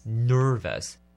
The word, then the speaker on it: nervous: Nervous 这个字叫做紧张的。 Nervous，你要记得了，ner 一定要卷舌念成 nervous。